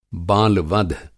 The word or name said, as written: बाल-वध